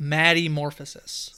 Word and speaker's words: Maddie Morphosis: Like with this person, Maddie Morphis, I think that's how it's pronounced. – I looked it up online, so it's Maddie Morphosis. So, like, metamorphosis, but Maddie Morphosis.